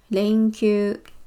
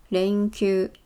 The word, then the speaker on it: 連休: 那它的日文呢念作连休，连休。